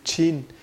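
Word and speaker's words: Chin (秦): Mà cái nước đó bây giờ thì mình gọi là China, cái chữ China là do chữ Chin. Chin, Chin là cái pronunciation của nước Tần, Tần đọc là Chin.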